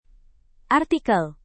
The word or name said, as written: artikel